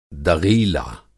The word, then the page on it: दग़ीला: Hindi